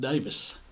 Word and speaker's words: Davies: And actually, they pronounce it Davis.